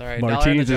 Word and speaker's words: Martins: Martins is how it's technically supposed to be pronounced, yes, according to the media guide on the site.